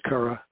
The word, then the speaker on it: Chara: But Chara Talisman, it looks like it's pronounced Chara, but it's based on a Greek word, so it's Chara.